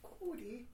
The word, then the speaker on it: Kid Cudi: Featuring Kid Cudi, or have we pronounced it? – Cudi. – Kid Cudi.